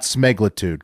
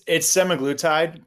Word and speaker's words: semaglutide: So you're telling us that it's not smeglitude, because we've been pronouncing it smeglitude for almost a year. – Mm-hmm. Yep. – Oh yeah. I mean, it's semaglutide, but then there's some people will call it semaglutide.